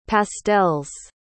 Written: pastels

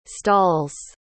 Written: stalls

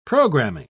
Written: programming

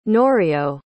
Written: Norio

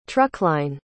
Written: truckline

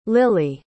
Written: lillie

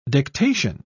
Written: dictation

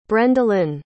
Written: brendalynn

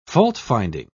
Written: faultfinding

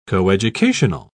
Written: coeducational

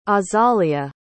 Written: azalia

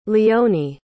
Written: leonie

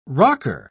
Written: rocker

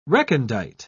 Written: recondite

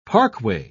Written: parkway

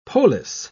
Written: polis

